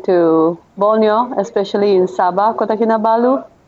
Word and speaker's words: Kota Kinabalu: Kota Kin Kin. I don't want to say it. – Kota, Kota Kinabalu in Borneo Island.